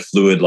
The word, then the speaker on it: Phluid: So I want to make sure that the Fluid Project is spelled P-H-L-U-I-D, Fluid.